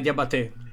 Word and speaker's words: Diabaté: No, è Diabaté, sarà sempre l'accento finale.